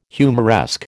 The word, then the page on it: humoresque: American English